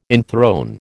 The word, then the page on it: enthrone: American English